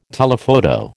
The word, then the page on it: telephoto: American English